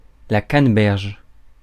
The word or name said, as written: canneberge